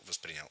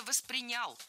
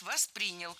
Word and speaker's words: воспринял: Ну, если вот мне по-простому, да, объяснить, вот я бы это так воспринял. – Да? – Что да. – Это не приставка. – Было сказано «воспринял», но это неверно. Правильный вариант «воспринял». Ударение в глаголе ставим на второй слог.